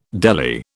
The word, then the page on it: deli: British English